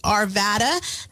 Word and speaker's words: Arvada: Yet again, another word that is commonly mispronounced is "Arvada."